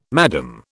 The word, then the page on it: madam: British English